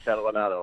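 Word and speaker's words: Catalanotto: I mean, he got burned obviously a little bit by Frank Candelario. Ah, I don't know how to say his name. – Catalanato. – Yeah, thank you.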